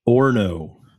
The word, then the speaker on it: Orno: You know, he's drinking a beer from Orano. How do you say that? – Orno. – Okay, so I was off. – It's a silent O.